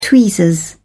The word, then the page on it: tweezers: British English